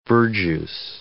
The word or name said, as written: verjuice